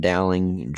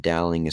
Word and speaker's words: Dowling: Dowling Jr. And Dowling is spelled Dowling.